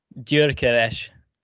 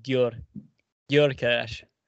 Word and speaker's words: Gyökeres: So it'd be Viktor Gyökeres. So it's like a DY sound at the beginning, like Gyökeres.